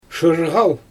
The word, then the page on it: ʃɯrɣal: Xyzyl